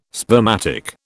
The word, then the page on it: spermatic: British English